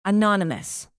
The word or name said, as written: anonymous